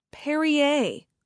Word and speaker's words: Perrier: "Perrier," I pronounced.